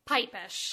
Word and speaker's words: Pipesh: Pipesh.